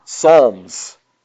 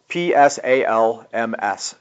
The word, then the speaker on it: psalms: Aha, psalms. P S A L M S, psalms. Even though it starts with an, it sounds like an S, it's a silent P.